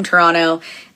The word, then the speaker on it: Toronto: Toronto. And did you notice I didn't pronounce the T at the end?